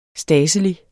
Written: stadselig

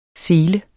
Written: selen

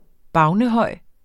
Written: BAVNER

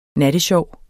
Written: nattesjov

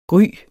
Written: gry

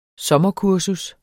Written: sommerkurser

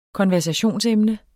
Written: konversationsemne

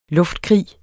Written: luftkrig

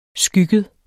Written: skygget

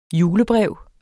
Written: julebrev

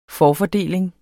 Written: forfordeling

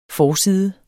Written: FORSIDERNE

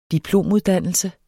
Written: diplomuddannelse

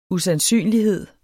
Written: usandsynlighed

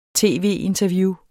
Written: tv-interview

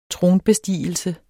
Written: TRONBESTIGELSE

